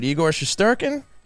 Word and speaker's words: Igor Shesterkin: And also a goaltender over on the New York Strangers that made it, Igor Shesterkin.